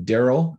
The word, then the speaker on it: Darrell: Darrell, which is spelled D-A-R-R-E-L-L, and then K.